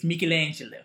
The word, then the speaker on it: Michelangelo: No, that's how they say it in Italy. It's Michelangelo.